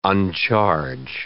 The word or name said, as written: uncharge